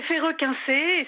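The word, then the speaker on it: Ferreux-Quincey: Ah oui, alors là, personne ne sait le prononcer. Euh, c'est c'est Ferrequincé,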